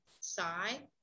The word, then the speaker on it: Sai: We have another person on board with us, and please forgive me, I'm not sure how to say, the name is S-A-I. Do we pronounce that? Sai?